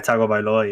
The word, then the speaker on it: Tagovailoa: Like, I feel, obviously, people still don't believe in Tua Tagovailoa. Did I say his name right? – Yeah, Tagovailoa, yeah. That's how you say it.